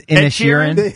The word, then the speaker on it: Inisherin: Colin Farrell for Banshees of Inisherin. Is that how you say it? – Yeah, ask Drab. He's seen it. – I think it's Inisherin.